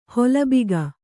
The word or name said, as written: ಹುಲಿಬೆಕ್ಕು